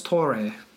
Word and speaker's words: Torre: So the story goes that Carlo Tor, Carlos... Carlo Tor, I don't know. – Carlos Torre. – Torre, yeah. Okay, you pronounce the E, sure.